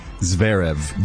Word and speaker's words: Zverev: Zverev.